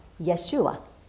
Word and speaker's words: Yeshua: The Hebrew name for Jesus is Joshua, more properly pronounced Yeshua, which means the Lord saves, or simply Savior.